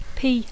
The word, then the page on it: pea: English